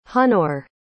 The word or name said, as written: hunor